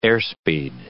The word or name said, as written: airspeed